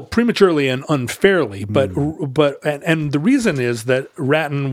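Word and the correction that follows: Rattín: was, and I hope I'm pronouncing that right, Ratin. – That sure, would be the French way. French. What is it, R-A-T-I-N? – R-A-T-T-I-N. – Yeah, it's probably Ratin.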